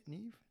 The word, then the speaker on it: Neve: Neve Campbell. Is that how you say it? Neve? – Yeah. – N-I-E-M-H. – Oh, no, it's N-E-V-E, right? – That one, yeah.